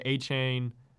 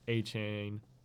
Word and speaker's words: Achane: You don't pronounce it like that. If you watch the game, I watched the game. – Devin Achane. Never in my life would I expect it to be pronounced like that.